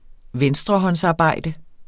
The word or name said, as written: venstrehåndsarbejde